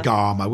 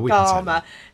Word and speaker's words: Gama: And overland, like Marco Polo, or by sea, like, now I never say his name right, Vasco da Gama? – Just say Gama. – Gama. Yeah. So English.